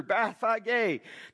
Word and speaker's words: Bethphage: That's how you pronounce it in Hebrew, Bethphage, to the Mount of Olives.